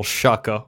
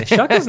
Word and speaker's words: Shuker: I've been rereading for the millionth time, a book called In Search of Prehistoric Survivors by a zoologist named Carl Shuker, or Shuker, I don't know how to pronounce it. – I prefer Shuker. – Yeah, Shuker's nice. – It's S-H-U-K-E-R, so that sounds like Shuker to me.